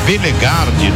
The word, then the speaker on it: Bellegarde: Coisa você me corrige aqui na pronúncia, hein? Que você sabe mais do que eu. – É Bellegarde, número 10.